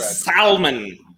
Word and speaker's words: salmon: Salmon. Salmon. You're a real asshole if you pronounce the L in salmon.